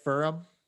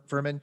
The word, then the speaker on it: Furman: So have you had a chance to look into, am I saying the name right, Furum? – Furman.